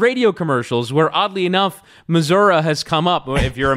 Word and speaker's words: Missouri: How do you pronounce the state that Kansas City, bigger Kansas City, and St. Louis are located in? Missouri? People in that state say Missouri. Missouri. Oh, Missouri.